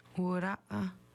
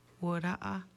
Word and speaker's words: Wura'a: Wura'a is how you say black bear in Nuwa. Wura'a.